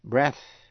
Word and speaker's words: breath: P-A-R-L. Learn là học. Earn là kiếm được. Early là sớm. Số 4 là EA phát âm là E trong breath là hơi thở, B-R-E-A-T-H.